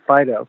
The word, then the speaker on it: Phideaux: You spell it P-H-I-D-E-A-U-X. You pronounce it Fido, like a French spelling.